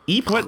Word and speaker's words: Ypres: Ypres. – It's spelled Y-P-R-E-S.